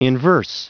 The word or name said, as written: inverse